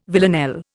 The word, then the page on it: villanelle: Indian